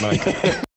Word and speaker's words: Nikon: Everyone else is pronouncing it wrong, for the record. It's Nikon.